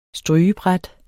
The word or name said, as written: strygebræt